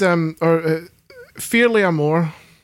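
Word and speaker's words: Fear Liath Mòr: And again, this, Fearlé Amor is the Gaelic, I believe is how it's pronounced, is how the creature is also known.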